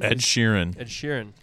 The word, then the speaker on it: Ed Sheeran: Next in the docket here, Ed Sheeran, is that how you say his name? – Ed Sheeran. Real person.